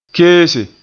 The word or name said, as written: keese